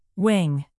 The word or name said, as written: wang